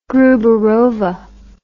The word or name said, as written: gruberova